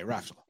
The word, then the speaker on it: Raftl: It's an L on the end. It confuses everybody. – It does. It's Raftl? – It's Raftl. Raftl. – Raftl. Raftl. Yeah. Okay, Raftl.